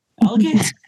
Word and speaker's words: algae: Is there a hard G in that word? Algae? – No, it's algae.